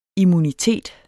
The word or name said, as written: immunitet